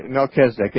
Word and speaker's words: Melchizedek: It's actually, Melchizedek.